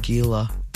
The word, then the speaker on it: Gila: I would have said Gila.